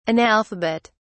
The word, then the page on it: analphabet: British English